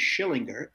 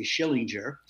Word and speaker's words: Schillinger: My last name is Schillinger. Everybody mispronounces it and say Schillinger.